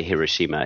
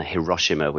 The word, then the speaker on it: Hiroshima: So, like, I believe the correct way to say Hiroshima is that way rather than Hiroshima, which is often how we would have said it in the West.